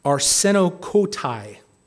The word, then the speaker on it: arsenokoitai: Arsenokoitai, that's the pronunciation.